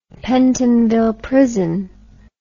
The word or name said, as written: pentonville prison